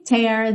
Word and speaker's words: tear: We're gonna twisty, twisty, twisty till we tear the house down. Lembra dessa pronúncia aí do verbo to tear, aberto, ok? Como se fosse um acento agudo, não é?